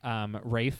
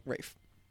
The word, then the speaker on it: Ralph: I'm like, Ralph? Nope, Rafe. – But, like, his name's Ralph, though. – Nope. It's Rafe, I guess.